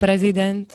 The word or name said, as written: prezident